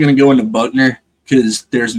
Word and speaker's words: Buchner: Because Ty Simpson and Tyler Buchner or Buckner or whatever the correct pronunciation of his last name is. Buckner. – I know. Yeah, it's Buckner. – It's Buckner.